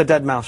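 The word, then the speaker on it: deadmau5: You're talking about the Dead Mouse Show. – Yes. – Yeah. – I didn't know how to pronounce that name because it was written in Leet.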